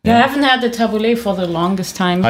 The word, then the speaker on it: tabbouleh: I would say the tabbouleh.